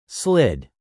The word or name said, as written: slid